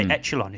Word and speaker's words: echelon: Yeah, you'll hear more upper echelons than lower echelons, that's for sure. – Is it a softer sort of ch? I always say echelon. It should be echelon.